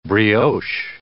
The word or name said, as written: brioche